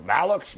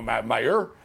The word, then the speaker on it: Mailloux: It's going to Malux, Mailloux, Mailloux. It's sort of, it looks French, but yeah, Mailloux.